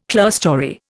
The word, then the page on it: clearstory: Indian